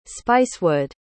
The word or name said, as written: spicewood